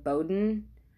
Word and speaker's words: Beaudoin: I think his last name is pronounced Bowden, but it is spelled Beaudoin.